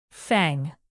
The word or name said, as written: fang